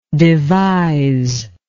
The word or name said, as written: deviser